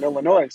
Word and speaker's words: Illinois: And they say, oh, no, you don't pronounce the S in Illinois.